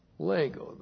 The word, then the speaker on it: lego: Lego, there we go. L-E-G, long O, lego.